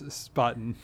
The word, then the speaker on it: Spaten: And also, I remember that I pronounced Spaten wrong. – What did you, I was actually not going to say anything, Matt, but yeah, it's Spaten.